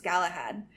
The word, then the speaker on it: Galahad: His name was Galahad. I'm sorry if I'm saying that wrong. – I think that's right.